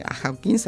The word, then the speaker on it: Hawking: Hawking, así se ve pronunciado.